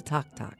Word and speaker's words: tac-tac: What is Tok Tok? Tok Tok, which is spelled T-A-C-T-A-C, is Cajun for popcorn.